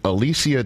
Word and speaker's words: Alicia: This is from a viewer of our program named Alicia Taylor. and I know I'm pronouncing the name correctly because it's phonetically spelled in the handle.